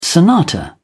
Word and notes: sonata: British English

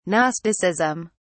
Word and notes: gnosticism: British English

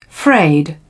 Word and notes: frayed: British English

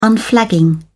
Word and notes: unflagging: British English

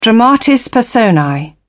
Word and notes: dramatis personae: British English

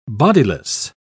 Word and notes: bodiless: British English

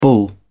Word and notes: bull: British English

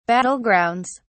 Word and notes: battlegrounds: British English

